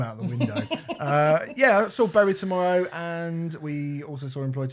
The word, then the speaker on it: Bury Tomorrow: Is it my hearing, or is it your pronunciation? I thought you said Bowie Tomorrow. – Bury Tomorrow.